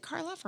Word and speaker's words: Karloff: Like you'll take Boris Karloff. Is that a person? Mm-hmm. Boris, is it Karloff or Carlyle? – Oh my God. Boris Karloff. – I said it right the first time.